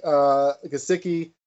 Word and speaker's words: Gesicki: Mike Gesicki, I think I'm saying his name right.